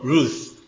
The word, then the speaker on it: ルツ: 輪島の勝田先生のお嬢さんは勝田ルツですね。そして、なんて言いますか、アメリカにもこのルツっていう名前はとても多くって、アメリカで英語式に読むとルース、ルースって読むわけですけど、